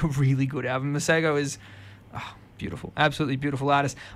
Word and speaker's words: Masego: I've seen it as Masego, but that's not right. It's Masego. Yeah. I've seen him pronounce it and I'm taking his word.